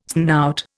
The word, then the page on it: snout: Indian